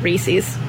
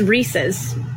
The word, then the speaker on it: Reese's: A pet peeve that I have that I'll never get over is people pronouncing it Reese's. It is Reese's. It is a guy named Reese and it is his. It's his. Reese's. And it's in the commercial. Reese's Puffs, Reese's Puffs. R-E-E-S-E-S.